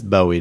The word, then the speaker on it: Bowie: So you got Pink, Garbage, Bowie, Weezer, E.T., and Karashi. Is that right? – I guess. – Did I say that right? – Yeah. That's Bowie, Dominic.